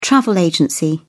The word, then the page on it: travel agency: British English